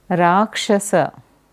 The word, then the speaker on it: Rakshasa: Rakshasa, Rakshasi.